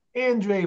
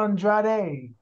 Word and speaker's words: Andrade: So for the men, for the fellas, is Mr. Sir Andre for the ladies, Sir Andrade.